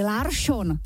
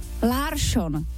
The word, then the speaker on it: Larsson: Protože celý večer říkali Larsson. Larsson. Chápeš to? Jako Larsson. Larsson. Mm-hmm. Vždycky to byl jako Švédi vždycky měli S a najednou tam bylo Š. Já jsem se pak na to koukala a opravdu k tomu jsou články. Nějaká odbornice na švédskou výslovnost říká, že je to takhle samozřejmě v pořádku.